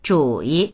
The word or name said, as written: zhu3yi